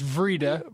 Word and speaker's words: Wrede: And I remember W-R-E-D-E. – I heard it pronounced Vrede. – Right. – I think he's German, but he's passed long ago.